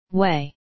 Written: way

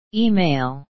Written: email

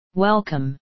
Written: welcome

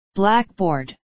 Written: blackboard

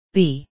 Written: bee

